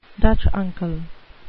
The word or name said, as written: dutch uncle